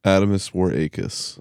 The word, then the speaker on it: Adomas Wareikis: Adamus Warakis.